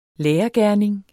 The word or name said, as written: lærergerning